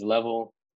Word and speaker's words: Leavell: And hey, shout out for you pronouncing the last name right. Not too many people pronounce it right. It is Leavell.